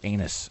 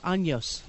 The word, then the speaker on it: años: Although I believe it is pronounced años, That's what I just said. – Oh, did you say años? All right.